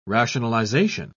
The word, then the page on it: rationalisation: British English